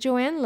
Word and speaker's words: Joan: So my name is Joanne Love, and I know it's spelled Joan, story of my life, J O A N Joan. But I'm from Puerto Rico, and in Puerto Rico, in Spanish, it's pronounced Joan.